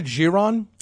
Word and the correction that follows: Givaudan: Givaudan. – Givaudan. – It's Givaudan.